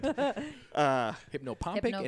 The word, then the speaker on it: hypnopompic: Hypnopompic and hypnagogic.